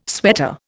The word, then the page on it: sweater: Indian